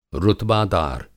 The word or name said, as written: रुतबादार